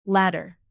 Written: ladder

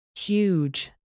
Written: huge

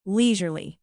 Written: leisurely

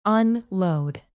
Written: unload

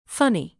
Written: funny

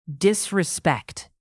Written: disrespect

